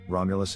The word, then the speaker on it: Romulus: In Latin, their names are pronounced with the accent on the second syllable, Romulus and Remus.